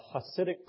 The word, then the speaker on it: Hasidic: There is a Hasidic proverb. Is that the right way to say it? A Hasidic proverb. Thank you.